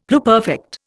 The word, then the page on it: pluperfect: Indian